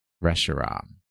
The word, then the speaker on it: Reshiram: Reshiram. – Reshiram. – No, it has to rhyme with Zekrom and Zekrom Kiram. and Reshiram. Zekram.